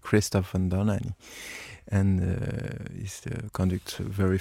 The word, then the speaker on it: Dohnányi: That's Dohnányi.